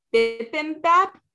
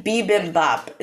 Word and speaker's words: bibimbap: And we didn't even get to Jay, who had the North Korean bibimbap. Is that bibimbap is how you say it? – Bibimbap.